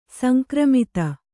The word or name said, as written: ಸಂಕ್ರಮಿತ